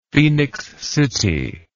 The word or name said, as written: Phenix City